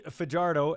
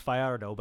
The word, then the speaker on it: Fajardo: Now, before we get to Cody Fajardo, as he said we can call him, although it's Fajardo, but nobody pronounces it right, so he's going with the easy way.